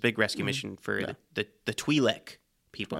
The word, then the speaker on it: Twi'lek: They say Twi'lek. I specifically remember that because I've always said Twi'lek, but they keep saying Twi'lek. I kept noticing them saying it that way. So it's pronounced Twi'lek people.